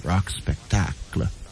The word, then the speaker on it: Rock Spectacle: See, we pronounce it in French, Rock Spectacle.